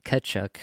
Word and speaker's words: Tkachuk: Tkachuk, sorry, I know I've been chastised by the online commenters for my pronunciation, but the T is silent.